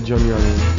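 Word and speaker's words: Johnny Hallyday: conosco io Johnny Oliver. Eh. – Ho detto bene. Sì. Eh col boccolone tutto rifatto tra l'altro insomma è una figura un po' ridicola se vogliamo ma è perfetto per questi film.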